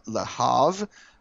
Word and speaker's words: Le Havre: Lys Mousset from Le Havre. Uh, I'm assuming that's pronounced like Favre, but uh, why not? – Uh, you pronounce it Brett Favre.